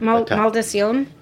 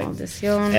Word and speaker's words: Maldición: Or Maldición. – That's the maker? – That's the cuvee name. – Let's spell that. Do we know how to spell that? – M-A-L-D-I-C-I-O-N. – Okay. – Maldición. – And the grape? – Malvar. M-A-L-V-A-R.